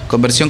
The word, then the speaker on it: Konverzión: Conversión con K, en Sion con Z, acento en la O.